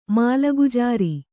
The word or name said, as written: ಮಾಲಗುಜಾರಿ